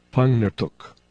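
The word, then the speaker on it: Pangnirtung: An exception occurs in words like, I'll spell it P-A-N-G-N-I-R-T-U-Q, because in Inuktitut, N-G is a single consonant. The pronunciation is Pangnirtuk, which translates to place with mature male caribou. You should have noticed that the Inuktitut R and Q are not pronounced like the English letters.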